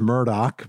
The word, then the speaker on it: Murdaugh: He spells it A-L-E-X, but everyone calls him Alec. I don't know why, but they do. His last name is Murdoch, but it's spelled M-U-R-D-A-U-G-H.